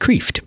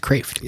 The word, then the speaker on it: Kreeft: You might think that his name is pronounced Kreeft, but it's pronounced Kraft.